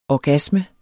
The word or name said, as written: orgasme